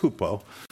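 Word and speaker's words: hupo (ὑπό): You kind of write it like H-Y-P-O, but it's hupo. So you can write H-O-O-P if you want. Doesn't matter. It's Greek. Who cares what you're writing it like in English as long as you can pronounce it. Hupo hyphen stasis, like S-T-A-S-I-S.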